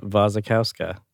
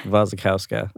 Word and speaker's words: Wasikowska: Mia Wasikowska.